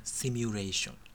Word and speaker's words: シミュレーション: だからシュミレーション、なんか思わず言っちゃいますよね、シミュレーション。